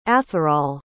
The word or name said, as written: atherol